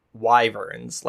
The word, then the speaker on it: wyvern: I think I used to call them wyverns as a kid, but I'm pretty sure the correct pronunciation is wyvern.